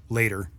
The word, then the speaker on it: L8R: The first one that comes to mind for me that I've got is by Boz Digital Labs called Later, L8R. and pronounced later.